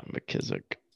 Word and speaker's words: McKissick: Gibson, McClizick, or however you say his name. – McScorin. Oh, you're talking about McKissick. – Yeah, McKissick.